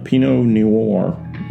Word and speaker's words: Pinot Noir: We typically do that over an adult beverage, and today we are drinking a Pinot Noir.